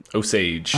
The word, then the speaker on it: Osage: Osage, I think. – Osage. – It's how it's pronounced in the film.